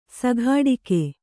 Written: ಸಿಕ್ಕಟ್ಟಿಗೆ

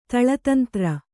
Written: ತಳತಂತ್ರ

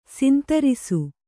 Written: ಶಾಂತರಸ

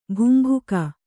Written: ಬುಂಭುಕ

